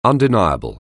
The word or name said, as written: undeniable